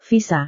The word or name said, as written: visa